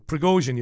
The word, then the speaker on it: Prigozhin: And you don't mean Prigozhin. Prigozhin, you mean. That's what you mean. Prigozhin, that's the guy.